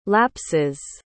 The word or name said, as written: lapses